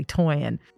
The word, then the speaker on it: Toyin: But just even like saying the name, like saying my name correctly, Toyin, it means a lot to me when you take a moment to say it correctly.